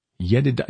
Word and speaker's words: Jedidah: Jedidah, yeah, that's right, because you transliterate the J to a Y, so Jedidah instead.